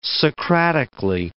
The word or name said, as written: Socratically